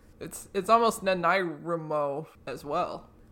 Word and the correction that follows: NaNoWriMo: I don't know how to pronounce the condensed version. – Nerm? – NaNoWriMo. There we go.